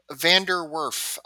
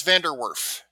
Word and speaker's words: Van der Werf: Van der Werf. It's pronounced basically like it looks in American English. And it's if you mispronounce it, it's fine. But it's Van der Werf.